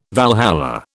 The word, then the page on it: Valhalla: British English